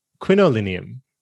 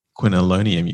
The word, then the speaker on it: isoquinolinium: Is it quinolinium? Quinolinium. What was I saying? – Quinolonium, you said.